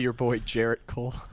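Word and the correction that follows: Gerrit: Garrett? – Yes, Garrett.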